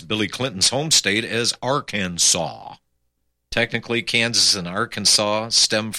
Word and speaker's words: Arkansas: Here's why we pronounce Kansas and Arkansas differently. Two state names, Arkansas and Kansas, share all but two letters in the exact same order. So why do we pronounce them differently? We can thank the French.